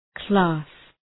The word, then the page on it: class: klæs